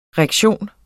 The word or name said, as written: rektion